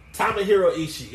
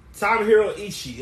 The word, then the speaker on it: Tomohiro Ishii: I can call him Tomario. I can't pronounce the guy's name. – Tomohiro Ishii.